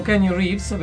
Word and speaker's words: Keanu Reeves: Potrei essere anche Dracula, quello con Keanu Reeves, perché immortale.